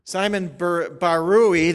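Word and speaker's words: Simon Barere: Simon Barui, that's how you pronounce his name, Simon Barui was one of the most renowned concert pianists in the world in the 1940s.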